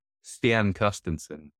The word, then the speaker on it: Stian Carstensen: I saw your video with Stein Carstensen as well. How do you say it? Yeah. Stian Carstensen.